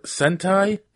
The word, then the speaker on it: Sentai: However, did you know that it's based on a Japanese show called Super Sentai? S-E-N-T-A-I-E? Or T-A-I.